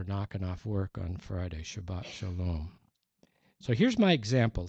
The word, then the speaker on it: Shabbat: If you wrote the word Sabbath down and handed it to an Israeli and said, read that, they would pronounce it Shabbat. TH is pronounced like a T. And a single S is pronounced like sh.